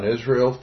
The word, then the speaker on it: Israel: Okay, my name is John Israel, full name John Warren Israel. My last name is I-S-R-A-E-L.